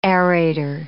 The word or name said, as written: aerator